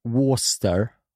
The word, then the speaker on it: Worcestershire: Ja, men det stavas ju Worcestershire. – Ja. – Men man uttalar det Worcester.